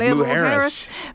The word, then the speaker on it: Mujeres: Had to phone the resort to get the pronunciation, but Mujeres.